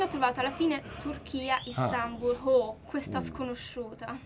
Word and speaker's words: Istanbul: Come mai sei finita a Istanbul?